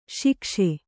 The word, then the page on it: ಶೈಕ್ಷ: Kannada